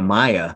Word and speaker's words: Maia: a, if I'm saying it correctly, a Maia, mm-hmm.